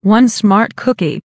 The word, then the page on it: one smart cookie: American English